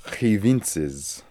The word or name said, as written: Hivintzes